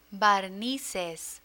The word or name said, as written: barnices